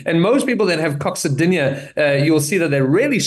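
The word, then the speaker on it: coccydynia: Are you able to help with a condition called, and I don't know if I'm going to even pronounce it right, coccidia? Coccidia? – There we go.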